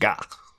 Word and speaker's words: gaht: The best case of what it means comes from some merchandise on his website, and G-A-H-T, got, I guess, I think the T is silent, so it's just got.